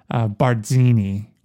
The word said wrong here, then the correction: Barzini: Oh, right. Bartzini.